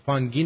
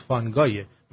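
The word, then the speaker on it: Fungi: فانگی نبود، فانگایه، من اشتباه تلفظ کردم.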